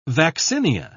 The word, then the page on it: vaccinia: British English